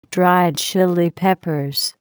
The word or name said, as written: Dried Chile Peppers